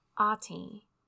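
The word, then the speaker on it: Até: and her name is Ate, and it's spelled A-T-E with an accent on the E.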